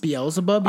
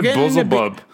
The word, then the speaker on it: Beelzebub: Is that how it's pronounced? – Beelzebub. – Yes. I always pronounce it Buzzlebub.